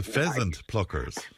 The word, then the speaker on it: pheasant pluckers: that I've pronounced this right. The peasant pluckers. – The pheasant pluckers.